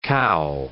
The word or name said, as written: cow